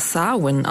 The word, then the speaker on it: Samhain: Ich spreche das aber Samhain aus, weil das auf Gälisch so ausgesprochen wird. Gälisch, das ist die ursprüngliche irische Sprache und in der werden ganz viele Wörter ganz anders ausgesprochen, als sie geschrieben werden.